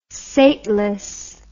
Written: sateless

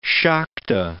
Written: Shakta

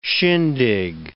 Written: shindig

